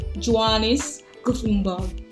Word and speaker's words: Johannes Gutenberg: I laughed because I don't really know how to pronounce the name some months back, but now I know how to pronounce it. His name is Johannes Gutenberg.